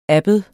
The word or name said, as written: abbed